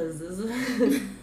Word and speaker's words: Pazuzu: I don't know if I'm pronouncing this right, but, like, Pazuzu. It's P-A-Z-U-Z-U. – That sounds about right.